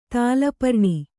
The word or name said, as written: ತಾಲಪರ್ಣಿ